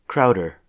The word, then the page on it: Crowder: U.S. English; Midwestern accent